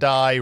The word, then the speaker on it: Dye: But the other thing he was known for was going for a tie in the 1988 Sugar Bowl with the Pat Dye rhymes with tie.